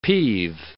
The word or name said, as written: peeve